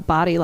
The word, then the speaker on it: Bodie: it's really called the Body Lighthouse, even though it's spelled really? – B-O-D-I-E.